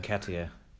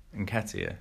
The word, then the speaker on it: Nketiah: Nketiah. – Nketiah.